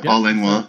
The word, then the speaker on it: Paul Langlois: How do you say his name? Yeah, Paul Langlois. – Perfect, yeah.